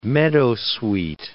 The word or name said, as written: meadowsweet